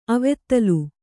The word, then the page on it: ಅವದಳ: avadaḷa